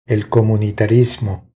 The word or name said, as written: El comunitarismo